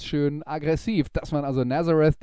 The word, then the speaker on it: Nazareth: Die Band heißt Nazareth oder korrekterweise auf Englisch ausgesprochen Nazareth und der Song, den wir uns anhören, heißt Expect No Mercy.